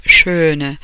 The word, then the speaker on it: schön: When you have that umlaut, the two dots over the O vowel in schöne, what you want to do is shape your mouth like O and pronounce A. Schön, schön, schön. Okay? The umlaut, two dots over the A in H-A-N-D-E, is pronounced E, Hände.